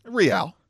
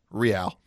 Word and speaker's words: Real: How do you pronounce it? – Real. – All right. Real Madrid. – You'll catch a lot of people. Real Madrid. No. Real.